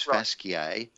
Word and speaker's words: fasciae: And fascia would be even more like the Latin, which is fasciae, F-A-S-C-I-A-E. But if you pronounce it like that, I think someone would assume that you were a very learned doctor going back to the Latin sources of your medicine.